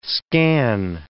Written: scan

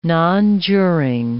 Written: nonjuring

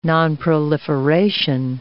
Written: nonproliferation

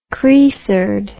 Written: chresard

